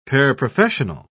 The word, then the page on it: paraprofessional: British English